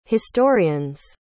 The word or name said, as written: historian's